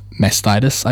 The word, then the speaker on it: mastitis: as well as an increased risk of mastitis, I believe is how you say it. – Yeah, that's right.